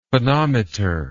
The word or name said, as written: phonometer